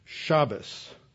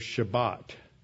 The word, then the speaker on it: Shabbos: So if you're among Eastern Europeans, they will say Shabbos instead of Shabbat, ending with a T.